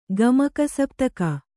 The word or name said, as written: ಗಮಕಸಪ್ತಕ